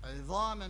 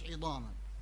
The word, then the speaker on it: عظاما: زي سابقتها عظاما مش عظاما